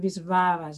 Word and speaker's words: Wisława: Door de L staat een streepje en die spreek je dan ook weer uit als een W. Dus dan moet je Wisława zeggen. Ja, op zijn Pools.